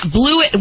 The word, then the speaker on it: Blewett: Yeah, Blewett. Well, I'm going to spell it. It's B-L-E-W-E-T-T, not like, you know, he blew it.